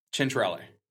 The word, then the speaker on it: Centrale: and I'm pretty sure that the pronunciation that you're looking for here is chin, almost like a C-H-E-N, Centrale. – Centrale.